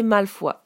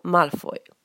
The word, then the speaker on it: Malfoy: En français, le nom de famille peut se prononcer Malfoy, mais en anglais on dit Draco Malfoy.